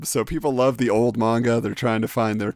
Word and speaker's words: manga: Just so you know, it's manga. Pronounced like conga, but with an M.